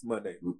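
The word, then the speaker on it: Moné: Monet. It's Monet. – I said what I said. – That's how she pronounces it.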